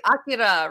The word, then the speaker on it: Akira: I'm trying to pronounce it the Japanese way, Akira, right? – Oh, yeah, that is right.